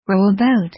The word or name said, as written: row a boat